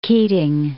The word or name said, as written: Keating